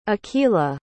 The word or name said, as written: akilah